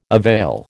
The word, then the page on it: avail: American English